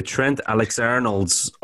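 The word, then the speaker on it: Trent Alexander-Arnold: The Trent Alexander-Arnolds, or the you know, are they going to Trent Alexander Arnold? – There you go.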